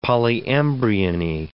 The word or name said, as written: polyembryony